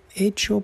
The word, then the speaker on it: hecho: They're going to sound the same because the H in Spanish is not pronounced. So echar un polvo o estar hecho polvo o echa polvo mean two complete different things.